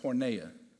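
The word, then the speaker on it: porneia (πορνεία): He says, put to death sexual immorality, which in the original writing, sexual immorality would have been pronounced porneia.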